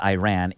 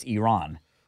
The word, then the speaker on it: Iran: Well, as we were educated, it's not Iran, it's Iran. You have to say it the right way.